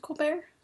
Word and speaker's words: Colbert: Does he still pronounce it Colbert? – I believe so.